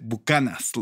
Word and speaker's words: Buchanan's: Ah, that would be the fabled Buchanan's. – Yeah, but we pronounce it Buchanan's, Lou.